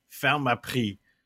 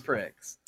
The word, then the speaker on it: Pharmaprix: But because price, prix, is spelled P-R-I-X. – That's pricks. – So in English, it just looks like Pharmaprix.